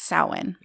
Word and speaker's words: Samhain: Now it's spelled like you would say Samhain, but it's Samhain. – Okay. – S-A-M-H-A-I-N, because, you know, the Celts just love to write it one way and say it another.